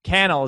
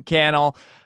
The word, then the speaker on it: Cannell: Sean Cannell, I say it wrong every time. Cannell is how you actually say it, right? – Sean Cannell rhymes with YouTube channel. – Nice. That's it. Sean Cannell.